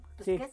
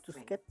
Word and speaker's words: Tusquets: Y es de la editorial Tusquets. – Sí. – Ahora sí pronuncio bien. – Tusquets. Sí, Tusquets.